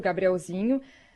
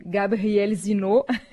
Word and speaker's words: Gabrielzinho: os apresentadores chamam de Gabriel Zinot, porque os franceses não conseguem pronunciar o NH, né? Eles colocam o acento sempre no final e não sabem falar o Nho, né?